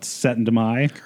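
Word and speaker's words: Syttende Mai: The 17th of May, I think we're talking about Setendemai. – Setendemai. – Setendemai, yes. Right. Big event in the Stoughton. – Big event. – Yes. – So Chris should be good here. Say it again. – Setendemai.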